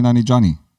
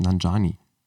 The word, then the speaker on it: Nanjiani: Aha, jetzt hast du da noch ein I eingebaut, was nicht da ist. – Wo jetzt genau? – Bei Nanjiani, der heißt Nanjiani. – Da, das J wird mit dem I davor ausgesprochen.